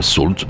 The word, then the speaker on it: Sault: Alors Salt, ça s'écrit S-A-U-L-T.